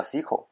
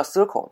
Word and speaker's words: SQL: 我我是我学也是在国外学的嘛，这大家都都是都是叫 SQL，但是为什么国内都叫都叫 Circle 呢？这是到底是从何而来的？这这个其实两种发音其实都是有的，两种发音都是有的。呃，应该是准确的说，呃，是没有一个标准的读法的，是没有一个标准的，就是读 Circle Circle 的和读 SQL 的啊，都都有。